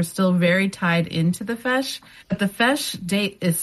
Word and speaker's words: feis: The Columbus Fesh, which is spelled F-E-I-S, is the Irish dance competition.